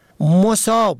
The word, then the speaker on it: مصاب: مصاب به ضم اول به معنای مصیبت زده، سختی دیده، مصیبت رسیده.